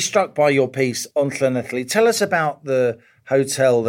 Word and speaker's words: Llanelli: Well, congratulations on the pronunciation, boyo. Get that tongue up in the roof of your mouth. – I watched Ivor the Engine as a kid. – Llanelli, Llanelli Bach.